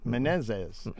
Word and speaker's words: Menezes: Undersecretary of Energy Mark Menezes, I don't know how to say this. Menezes. – Close enough. – Sounds like I'm not saying it right. M-E-N-E-Z-E-S. – Yeah. – Menezes. – Menezes. Okay. All right.